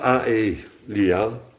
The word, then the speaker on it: Aelia Capitolina: Colonia Elia Aelia Capitolina, met een C, Capitolina.